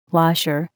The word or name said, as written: washer